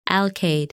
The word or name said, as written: Alkaid